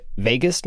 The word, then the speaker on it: vagus: And this is related to the is it pronounced vagus nerve? – Right.